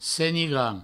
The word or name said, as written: senigrand